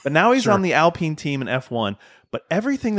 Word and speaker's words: Alpine: It's French. Yep. – Mm-hmm. It's called Alpine. – Yep. Yep. Really hit the emphasis on the second syllable.